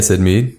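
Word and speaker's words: Syd Mead: Yeah, Said Mead.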